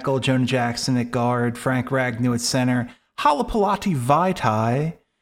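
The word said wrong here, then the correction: Halapoulivaati Vaitai: at right guard, and Penny Sewell at right tackle. Halapulavati Vaitai. Apologies. – Thank God you pronounced it correctly.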